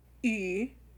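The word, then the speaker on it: Yu (鱼): Yu.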